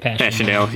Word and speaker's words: Passchendaele: Passchendaele, yeah.